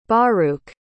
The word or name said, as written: baruch